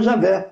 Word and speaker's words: Javé: Fala assim, olha, a esmagadora opinião acadêmica depende que as quatro que o tetragrama no tempo de Moisés se pronunciava Javé.